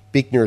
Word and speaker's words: Buechner: But Buechner is his name, at least as I hear it pronounced by most in American, although I think if you had German pronunciation, it might be a little different.